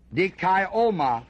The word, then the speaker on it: dikaioma: The word ordinances here in chapter 9 is the Greek word dikaionia, D-I-K-A-I-O-M-A, dikaioma, that D-I-K-A-I-O-M-A. That's a long O.